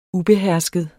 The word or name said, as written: ubehersket